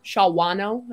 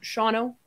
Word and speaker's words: Shawano: Because I'll have, that was one thing we did at Miss America is I was like, somebody pronounce this. And Shawano. And I'm like, no, just Shawno. Just let it roll. Just Shawno. That's it.